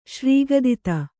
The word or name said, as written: ಶ್ರೀಗದಿತ